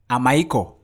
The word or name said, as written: AMAIKOR